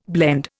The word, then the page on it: blende: Indian